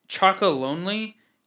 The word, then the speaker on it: Chocolonely: Chocolonely, that's how it's supposed to be pronounced.